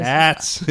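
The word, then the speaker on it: Aarts: How do you say it? – Arts. It's double A-R-T. – Yeah, Arts. – Arts. Arts. It's got a double A. – Arts. – No. – Like Aardvark. – It's a silent A.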